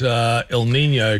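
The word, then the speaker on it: El Niño: Then I've got El Nino, correctly pronounced. I did say El Nino. It means the boy in Spanish, named because it was typically observed.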